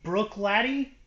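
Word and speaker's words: Bruichladdich: Brook Laddie is how I've been told to pronounce it.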